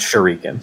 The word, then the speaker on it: shuriken: Like a shuriken. I've never heard shuriken. – It's pronounced shuriken.